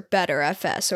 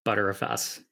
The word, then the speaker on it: Btrfs: So before we get into talking about ButterFS itself, how do you pronounce BTRFS or BetterFS or BTreeFS? – I pronounced ButterFS as ButterFS. – That's cool because a lot of people just have different ways of pronounce. I just call it BetterFS.